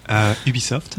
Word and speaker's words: Ubisoft: Ubisoft.